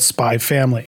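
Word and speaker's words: Spy × Family: The title of the movie is spelled Spy X Family Code White, with an X between spy and family. However, the X isn't said and the franchise is just called Spy Family.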